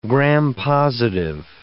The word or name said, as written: gram-positive